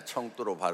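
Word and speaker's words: 청두로 (成都): 쓰촨성 지역은 제가 처음 가봤는데 청두라고 우리 한자로는 성도라고 쓰는데 청두로 발음을 하죠. 와, 거기에 한 만여 명이 모였었습니다.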